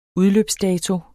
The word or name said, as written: udløbsdato